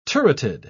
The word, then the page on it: turreted: British English